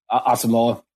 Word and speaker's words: Asamoah: I've had a couple people tell me uh, pronounce his name for me, uh, Brian uh, Asamoah. Asamoah.